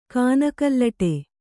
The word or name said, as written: ಕಾನಕಲ್ಲಟೆ